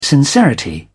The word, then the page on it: sincerity: British English